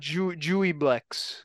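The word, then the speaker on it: Juiblex: I'm going with Jubilex. – I don't know how to pronounce this. What is that? – Jueyblex. – Yeah, Jueyblex.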